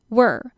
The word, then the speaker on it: were: 那这个 were 呢，就是它的音不是长音哦，它是 were， were，它跟 her 是有一样的音的，所以这个是第二个容易被搞混的念法。第三个呢是 where， where，W-H-E-R-E，哪里的那个 where，这个 where 呢，它的音是跟 hair 头发，它们的发音是一样的 where， where。好，所以我们再重新复习一遍哦。如果是 we 加上 r 是变成 we're，如果是 W-E-R-E 那要念作 were，那如果是 W-H-E-R-E 哪里的话就要念作 where。OK，所以这个大家可以多多练习。那我们接下来看下面两个字 attracted to， attracted to，这边的 to 你可以念作 t 的音， we're all so attracted to，然后后面再加 people。